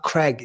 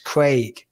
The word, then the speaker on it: Craig: It's not Craig. It's Craig. We don't say Craig in the UK, but if I fucking mispronounce one word, oh my God, I've got everyone coming at me.